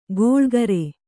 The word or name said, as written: ಗೋಳ್ಗರೆ